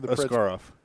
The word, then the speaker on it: Askarov: Say that guy's name again. – Askarov. Yaroslav Askarov. – You sound like Tom Brokaw when you said it. Yaroslav Askarov. – Askarov. – Okay, glad you can pronounce that.